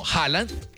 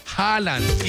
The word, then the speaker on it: Haaland: Lo han adaptado a Haaland con J y L, ¿no? Haaland. A ver, se escribe H-A-A-A-L-A-N-D. Así se escribe, Haaland. Y pues lo pronunciamos acá como Haaland. Pero se escribe H-A-A-L-A-N-D.